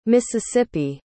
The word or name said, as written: Mississippi